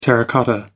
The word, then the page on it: terracotta: American English